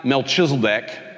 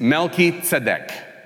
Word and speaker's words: Melchizedek: And so the way you pronounce it is not Melchizedek, as if he's chiseling decks. It's Melchizedek. Melchizedek.